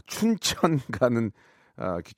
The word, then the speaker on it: 춘천: 제가 발음이 좀 안 좋았나 봐요. 예, 추천 가는 기차라고 들렸다고 하는데요. 아, 죄송합니다. 춘천 가는 아, 기차였고 우리 구진선님은 지금 우연하게 춘천에 가고 계시는데 이 노래가 나왔다고 좋아하셨습니다.